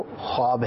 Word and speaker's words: ख्वाब: कई मरतबा कॉल्स के अंदर ख्वाब कहा जाता है, तो जो सही इसका तलफ़ुज़ है वो ख्वाब है। उर्दू के अंदर कई ऐसे अल्फाज हैं कि जिसमें वाव लिखा जाता है लेकिन वो पढ़ा नहीं जाता, तो वो साइलेंट होता है, तो ख्वाब भी ख्वाब कहा जाएगा, ख्वाब इसको नहीं पढ़ना चाहिए, बोलना भी नहीं चाहिए, पढ़ना भी नहीं चाहिए।